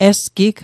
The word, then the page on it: esgig: Mi'gmaq